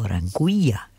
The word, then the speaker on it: Anguilla: This one's called, and it's his latest, Anguilla, or Anguilla, if you pronounce it in Spanish, A-N-G-U-I. Double L-A is Sunburn. Sunburn, of course, is spelt all in lowercase, S-U-N-B-V-R-N.